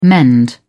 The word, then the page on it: mend: British English